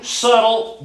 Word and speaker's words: subtle: But listen, just like debt, just like comb, just like plum, just like crumb, you don't pronounce the B in that, do you? So the devil is the same subtle beast.